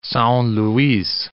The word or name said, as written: Sao Luis